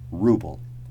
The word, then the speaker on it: ruble: Note the pronunciation, ruble.